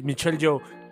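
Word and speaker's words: Michelle Yeoh: Michelle Young. Michelle Young. – Michelle Young, no, sé em, cómo pronunciarlo. Joe. Sí, Michelle Joe.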